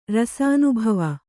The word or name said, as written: ರಸಾನುಭವ